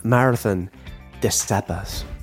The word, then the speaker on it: Marathon des Sables: So uh, I'm going to start off with marathon disables. That's how they say it.